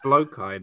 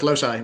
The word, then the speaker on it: Loci: You mean Genius Loci? It's Loci, no? – No, it's Loci. Well, they pronounced it Loci on the nominations. – Well, they're wrong.